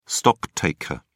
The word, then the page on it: stocktaker: British English